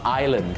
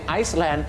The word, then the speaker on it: island: Island ก็พอครับถ้าไปออกเสียง Iceland